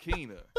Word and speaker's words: Kena: It is Kena, Bridge of Spirits, not Kena, Bridge of Spirits.